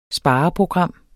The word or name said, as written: spareprogram